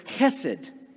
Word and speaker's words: chesed: chesed.